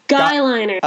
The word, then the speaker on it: guyliner: Gayliner. – No, not... Guyliner.